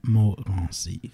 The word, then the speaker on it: Morancy: Sometimes I say it so that Americans can remember it and pronounce it. It's rather trivial, but it is what it is. But my name is Ruben Morancy.